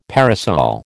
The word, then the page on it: parasol: American English